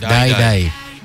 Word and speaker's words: Dai: D-A-E. – D-A-I. – Die.